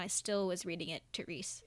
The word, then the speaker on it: Therese: Carol 2, Therese, because apparently that's the way that you pronounce it. I thought that it was Therese.